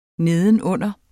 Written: nedenunder

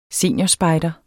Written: SENIORERNES

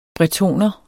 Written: bretoner

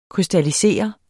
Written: krystallisere

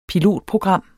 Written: pilotprogram

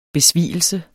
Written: besvigelse

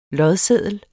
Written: lodseddel

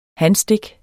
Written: hanstik

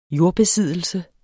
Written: jordbesiddelse

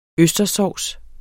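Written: østerssovs

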